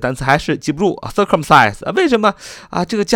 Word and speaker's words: circumcise: 这个 circumcise 是由三个部分组成。第一个 c i r c u m，circum 这是一个词根，意思是圆的意思。第二部分是 c i s，就是切的意思。第三部分是 e 啊，放在字尾不发音，没有任何的意义。